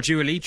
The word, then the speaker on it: Dua Lipa: Is that how you say her name? – Oh, Dua Lipa.